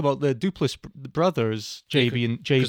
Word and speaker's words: Duplass: And they're played by, it's Patrick Brice, if I'm saying that right, he directed it, and it was written by Brice and, is it Mark Duplass? – Mark Duplass, yep. Duplass.